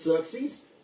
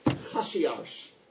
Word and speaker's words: Xerxes: Why do I think he was Xerxes? Because in Persian pronunciation, Xerxes is actually a Greek pronunciation, Xerxes. But in Persian, it's pronounced Hashiarsh. Hashiarsh, what's the Hebrew?